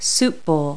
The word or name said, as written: soup bowl